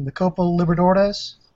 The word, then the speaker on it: Copa Libertadores: Because I think he played in the, and I'm going to butcher this name, the Copa Libertadores. I don't know. – Okay, yeah. – That's the way to pronounce it.